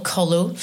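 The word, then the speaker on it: Kollo: And how do you spell it? – K-O-L-L, Collo.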